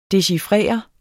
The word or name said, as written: DECHIFRERES